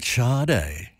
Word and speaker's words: Sade: And we had a listener phone in who was picking me up on that little anecdote about Sade. Yes. Now, I forgot to mention that Sade is spelled S-A-D-E, which in French is Sade.